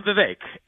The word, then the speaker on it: Vivek: So I'm Vivek. It rhymes with cake, and I have lived the American dream.